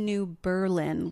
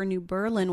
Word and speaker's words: New Berlin: and in the Wikipedia entry for New Berlin, Wisconsin, it was very clear that you should put the emphasis on the first syllable. – Absolutely. New Berlin, not New Berlin.